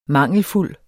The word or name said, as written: mangelfuld